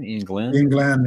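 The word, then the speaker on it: Iain Glen: Is that how you say his name? Ian Glan, yeah.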